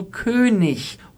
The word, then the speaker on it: König: Es ist nämlich nicht ich, sondern äh, am Wortende im Hochdeutschen immer ein Ich, also König, Honig, wenig.